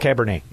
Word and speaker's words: Cabernet: I don't drink, so I probably butchered it. Patrick and Schomburg. Cabernet. – Sean. – It's Cabernet. Yeah.